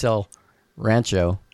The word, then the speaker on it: El Rancho: I would compare Matt's El Ranchi. I mean, El Ranch. Now I can't say it right. Matt's El Rancho. – Rancho.